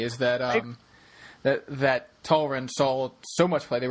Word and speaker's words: Talrand: He, exactly, the sky summoner himself, uh, Talrand, not Tarland,